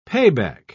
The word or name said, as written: payback